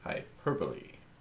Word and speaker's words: hyperbole: One of those words that I cannot pronounce. Kind of like hyperbole. – Hyperbole.